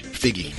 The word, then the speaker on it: Feige: Hey, Kevin Feige. I am pronouncing that right. Kevin Feige. – Figgy. – Figgy.